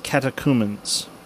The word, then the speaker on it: catechumens: It's spelled C-A-T-E-C-H-C-U-M-E-N-S, but it's pronounced catechumens.